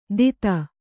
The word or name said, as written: ದಿತ